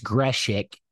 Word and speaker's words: Greshick: So Daniel Greshick, it doesn't look like that on, but obviously I'm a pronunciation guide guy, so I found out that it's Greshick, Utah State transfer, the dog linebacker.